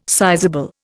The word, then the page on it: sizeable: Indian